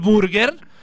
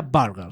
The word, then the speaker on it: burger: Porque sí, amigos, no se dice, oye, esta noche cenamos en el burger se pronuncia burger. – Burger. – Burger. – Burger King. – Burger King. Además tienes que eh, sí, alargar I. Es una I larga.